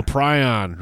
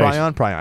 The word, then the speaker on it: prion: Prion, right. – Prion. I never heard how to say it. Prions. – Prion.